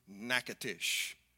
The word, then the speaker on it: Natchitoches: Now, if you're traveling through this region of Louisiana, by the way, you might want to pronounce it the way that the locals do, Natchitoches.